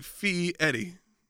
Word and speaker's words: Fieri: It's like Fieri. – Yeah. – Like, you're feeing. – Even though it's spelled Fieri. – It's like you're feeing Eddie.